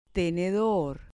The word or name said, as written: tenedor